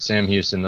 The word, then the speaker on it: Sam Houston: Yep, Sam Houston.